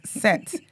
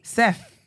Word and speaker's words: Seth: I said, this is what you lot are pronouncing as Set. Seth. Like, T-H.